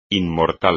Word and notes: inmortal: Spanish

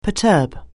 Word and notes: perturb: anglais

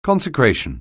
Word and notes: consecration: anglais

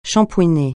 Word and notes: shampouiner: francais

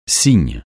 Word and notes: Cygne: francais

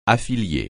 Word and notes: affilié: French

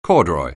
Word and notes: corduroy: anglais

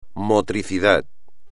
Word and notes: motricidad: espagnol

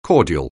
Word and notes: cordial: anglais

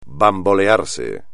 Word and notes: bambolearse: espagnol

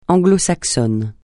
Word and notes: anglo-saxon: francais

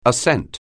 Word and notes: ascent: anglais

